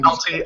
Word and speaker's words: Atelier: I like it when Atelier.